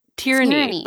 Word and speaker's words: Tyranny: Tyrion. – It's Tyranny. Like, just like word. Tyranny. It's, like Darth Tyrannus. Tyranny.